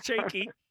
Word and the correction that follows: Kiki: No, Kiki. – Kiki. Oh, yeah, like K-I-K-I.